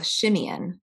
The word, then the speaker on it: Shimeon: So, so um, Simon would be the Greek pronunciation of of Shimeon, which is heard.